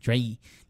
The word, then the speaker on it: Draenei: Draenei.